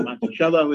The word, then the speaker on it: Monticello: And in fact, in his library in Monticello, some people call Monticello, but Monticello is the correct pronunciation.